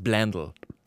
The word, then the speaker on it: Blendle: Und bei Blendle aus den Niederlanden, spricht man das überhaupt so aus? – Ich glaube, man sagt Blendle. – Blendle? – Ein bisschen englischer.